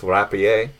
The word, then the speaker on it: rapier: If you want to go for the French pronunciation, it's rapier.